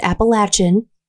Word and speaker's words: Appalachian: I would like them to know that I am aware it is pronounced Appalachian. – I think it's an either or thing. – No, it's not, because all of the people that live there pronounce it with the ch.